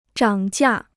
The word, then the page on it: 涨价: zhǎng jià